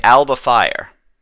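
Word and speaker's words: Alba Fyre: So we would say it Alba Fire. It's ALBA, just as it sounds, and FYRE.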